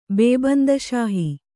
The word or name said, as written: ಬೇಬಂದಶಾಹಿ